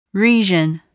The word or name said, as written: region